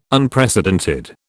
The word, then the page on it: Unprecedented: British English